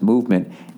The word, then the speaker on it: MVMT: B-U-R-R. Two more, everybody. Hang in there. Movement Watches, everybody. Movement Watches, pronounced Movement, M-V-M-T. That's how they spell it, was founded on the belief that style shouldn't break the bank.